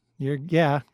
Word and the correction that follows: cerebellum: Pronounce it a little bit differently, and you're getting there. – Oh, okay. Cerebellum? – Cerebellum is correct, with the E-R-E-B-E in the middle.